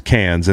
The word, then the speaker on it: Cairns: It's spelled like Cairns, but it's Cairns.